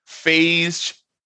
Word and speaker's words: phage: Phage technology is it phage technology with with the yeah. soft G?